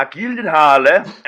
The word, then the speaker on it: Gyllenhaal: Ja, also ich habe da eine relativ vertrauenswürdige Quelle in Form von, ja, Gyllenhaal, als er seines Zeichens zu Gast bei Conan O'Brien war.